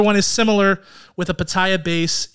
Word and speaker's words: pitaya: I'm adding an extra ya in there. – Pitaya. – No, I was saying it right.